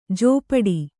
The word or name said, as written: ಝಪಾಟಿ